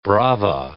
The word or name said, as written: brava